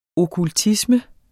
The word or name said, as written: okkultisme